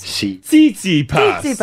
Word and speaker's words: Tsitsipas: Tsitsipas.